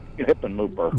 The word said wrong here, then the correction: Hickenlooper: Yeah, he likes to say his name. Hickenlooper.